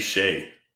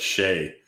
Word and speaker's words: Skjei: The other one that gets me is Brady Shea. I don't know how the hell look at that and get Shea. – Yeah, it's spelled like S-J, right? – S-K-J-E-I.